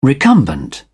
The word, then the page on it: recumbent: British English